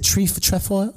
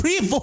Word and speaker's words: trefoil: Is it trefoil? – No, it's trefoil. – I feel like that's a French word, and I feel like it's not pronounced trefoil. – It is pronounced. You can't say something is not what it is when I've told you it's what it is and shown you a picture.